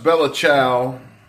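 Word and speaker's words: Bella Ciao: Well, guys, we're going to watch Bella Chow.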